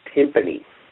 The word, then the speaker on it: timpani: Are you kidding me? It's pronounced timpani. Timpani.